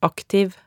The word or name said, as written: aktiv